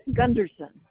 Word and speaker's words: Gunderson: That's Greg Gunderson with a G.